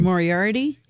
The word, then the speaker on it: Moriarty: But Leanne Moriarty, can never pronounce her name. – Moriarty? – Thank you.